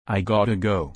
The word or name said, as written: I gotta go